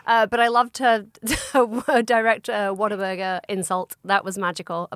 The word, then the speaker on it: Whataburger: Whataburger. I would say what a burger. – Yeah. – And in Texas, they were like, Whataburger.